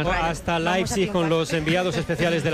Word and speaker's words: Leipzig: Es Leipzig, es lo que nos dijeron que teníamos que decir.